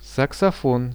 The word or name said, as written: saksofon